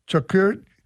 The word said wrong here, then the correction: charcuterie: It's some boards. – Charcuterie board, yeah.